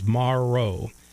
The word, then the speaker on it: Morrow: The MRO is actually how you pronounce my last name if you say it with the letters, Morrow. A lot of people say marrow, or it's not bone marrow. It is like tomorrow.